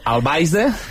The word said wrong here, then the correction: Alvise: Bastante mal pronunciado, pero es correcto. Alvise Pérez.